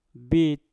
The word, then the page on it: bit: mpur